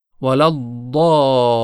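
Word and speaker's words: الضالين: در تلفظ حرف ضاد فرمودن حرف ضاد از تماس کناره زبان با دندانهای آسیای بالا تلفظ میشه. طرف راست یا چپ تفاوت نمی کنه، اما تلفظ ضاد از طرف چپ آسان تر هست. بنابراین کناره زبان به دندانهای آسیای بالا ترجیحاً سمت چپ متصل میشه و این حرف تلفظ میشه، غیر المغضوب ولا الضالین، غیر المغضوب ولا الضالین.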